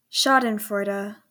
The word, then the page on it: Schadenfreude: /ˈʃɑːdənfrɔɪdə/